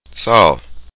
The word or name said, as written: sagol